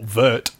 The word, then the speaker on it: wort: Or vert.